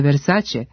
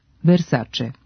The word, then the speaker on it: Versace: Iako se prezime poznate italijanske porodice Versace čita sa ć, ovakav izgovor nema uporište u pravopisu. Prema pravilima transkripcije, italijansko c se ispred vokala e i i čita kao č, Versace.